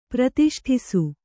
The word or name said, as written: ಪ್ರತಿಷ್ಠಿಸು